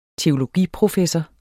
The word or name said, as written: teologiprofessor